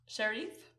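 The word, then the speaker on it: Sherif: Okay, so the first study I'm going to talk about, I think his name is pronounced Muzaffar Sharif. – Sharif? – Sharif.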